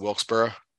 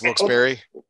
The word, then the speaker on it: Wilkes-Barre: I've always pronounced it Wilkesboro. And it's Wilkes-Barre.